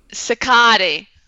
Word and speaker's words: Cicotte: Cicotte, is that how you say it? – Cicotte.